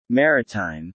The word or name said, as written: Maritime